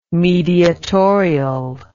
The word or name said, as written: mediatorial